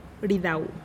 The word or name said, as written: ridau